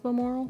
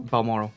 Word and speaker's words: Balmoral: How do you pronounce Balmoral? – Balmoral.